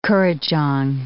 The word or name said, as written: kurrajong